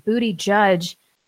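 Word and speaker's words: Buttigieg: The least in the kingdom says it's pronounced booty judge, no cap.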